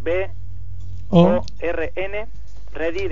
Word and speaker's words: born ready: Es B O R N ready de preparado. – nacido preparado. Pronuncia bien, born ready.